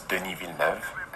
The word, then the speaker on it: Villeneuve: My name is Denis Villeneuve, and I'm the director of Arrival.